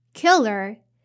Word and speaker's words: killer: And now the third pronunciation tip is killer. So the er here is a unstressed vowel, so you don't have to raise your intonation. So it's like uh uh it's not er is uh so killer meal.